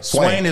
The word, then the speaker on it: Swain: When someone asks you, hey, Swain, you know, if I say your name properly, they would have to say it correctly. I can call you Swain. – Swain is my name.